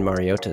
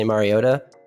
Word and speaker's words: Mariota: The correct Hawaiian pronunciation of Marcus Mariota is to enunciate the T. And if I'm talking really fast, I'll say Mariota, like it has a D. But if you want to be proper, which I just do sometimes because I'm a loser, I say Mariota.